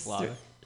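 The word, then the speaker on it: Slavoj: but I was in the middle of watching Slovak. Okay, I cannot pronounce this. – Slavic. – Yeah, Slavik.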